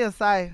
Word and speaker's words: acai: Say it correctly? – Acai. – Acai bowl. Not acai.